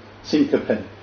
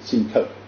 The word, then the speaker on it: syncope: I have difficulty walking, and it can lead to syncope, or as some doctors pronounce it, syncope, means fainting.